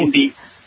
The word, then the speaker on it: Hindi: Then I went back to French in college, and then now I'm studying Hindu. – Ooh. – Hindi. Sorry, said it wrong.